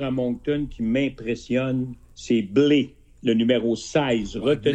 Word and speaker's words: Bleyl: Tommy Blé. Alors, ça s'écrit. Ça s'écrit pas comme le blé dans nos champs, là. Ça s'écrit B-L-E-Y-L. Blé.